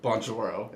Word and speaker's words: Bonjoro: So, why did you start Bonjoro? – And am I saying that right? You're saying it perfectly.